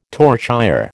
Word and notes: torchère: American English

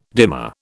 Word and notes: dimer: British English

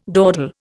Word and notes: dawdle: Indian

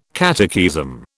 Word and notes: catechism: British English

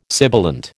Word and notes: sibilant: American English

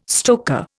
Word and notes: stoker: Indian

